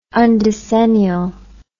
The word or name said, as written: undecennial